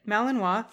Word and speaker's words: Malinois: Oh, it even tells me how to say it. Malinois.